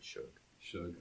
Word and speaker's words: Suge: So once these guys kind of got to a level of success, NWA kind of went off on their own direction with Suge. Did I say that right? – Suge.